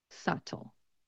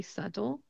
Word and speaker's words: subtle: And I don't really pronounce the T in the word. The Californian accent, one of the things it's known for is turning the T's into D sounds. So I say subtle, subtle.